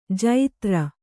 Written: ಜತ್ರು

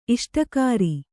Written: ಇಷ್ಟಕಾರಿ

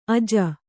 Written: ಅಜೆ

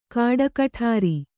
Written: ಕಾಡಕಠಾರಿ

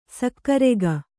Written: ಸಕ್ಕರೆಗ

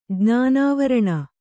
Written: ಜ್ಞಾನಾವರಣ

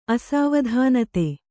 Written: ಅಸಾವಧಾನತೆ

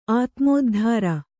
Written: ಆತ್ಮೋದ್ಧಾರ

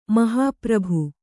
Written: ಮಹಾಪ್ರಭು

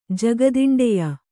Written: ಜಗದಿಂಡೆಯ

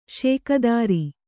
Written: ಷೋಕೀದಾರ

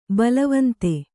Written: ಬಲವಂತ

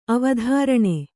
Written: ಅವಧಾರಣೆ

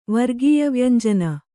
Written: ವರ್ಗೀಯವ್ಯಂಜನ

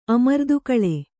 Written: ಅಮರ್ದುಗೂಳ್

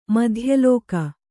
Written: ಮಧ್ಯಲೋಕ

